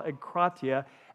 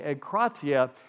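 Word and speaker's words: egkratia (ἐγκράτεια): The word is called egkratia. And so E-G, in English, E-G. And then K-R-A, and that's where the accent is on the second syllable, egkratia. And then it's T-E-I-A, egkratia.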